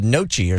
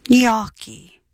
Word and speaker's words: gnocchi: It is not gnocchi. It's gnocchi. Please say it correctly. Gnocchi.